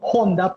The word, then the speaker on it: Honda: Y, y aquí aprovecho y hago cuña Benjamín trabaja para Honda y se dice así Honda porque la H suena Honda, no Honda, ¿ok?